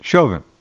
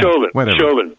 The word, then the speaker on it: Chauvin: So leading up to all this stuff, I'm very upset about what I saw originally, which was Derek Chauvin, if I said his name right. We kind of discussed that. Chauvin. – Yeah, Chauvin. – Yeah. – Like chauvinist, right? – That's it.